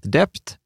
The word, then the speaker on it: debt: Det, det som stör mig lite är hur du uttalar ordet dept. B i ordet är tyst, det uttalas således det.